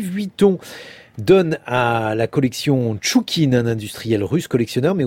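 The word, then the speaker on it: Chtchoukine: Son nom, Sergei Tchoukine, alors c'est compliqué, ça s'écrit Tchoukine, mais moi, j'ai vraiment compris qu'on dit Tchoukine.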